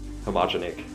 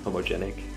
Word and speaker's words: Homogenic: Um, homogenic, homogenic however you want to put the emphasis.